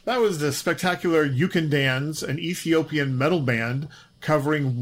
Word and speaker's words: Ukandanz: It's spelled U-K-A-N-D-A-N-Z, You Can Dance.